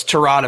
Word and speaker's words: Tyrod: Yeah, or Tyrod, is that how you say his name? I always called him Tyrod. So it's Tyrod, apparently.